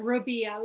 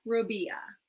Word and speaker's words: Robia: You are Robia, like Maria Robia.